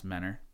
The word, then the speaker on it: Mentor: It's actually pronounced Mentor, if you're from there.